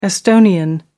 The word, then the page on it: estonian: American English